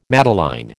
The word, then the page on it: madeleine: American English